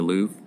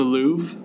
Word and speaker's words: Louvre: Did I say that right? The Louvre? – The Louvre? – Yeah. – Yeah.